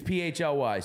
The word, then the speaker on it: PHLY: I've seen some people discussing how we pronounce it and all that. It's PHLY.